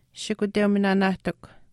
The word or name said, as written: shikuteuminaanaahtikw